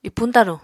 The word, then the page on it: ipuñ-taruñ: apatani